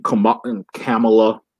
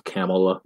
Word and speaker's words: Kamala: And then now you go on like freaking Twitter, you see Kamala. – How do you say, while we're on that, how do you say her name? – I think it's like a camel, Kamala. I used to say Kamala, but yeah.